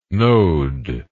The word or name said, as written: knowed